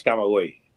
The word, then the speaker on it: Camagüey: Camagüey? – The St. Provincia. There it is. – How do you pronounce it? The Camagüey? – Yes, that's Camagüey. – Camagüey.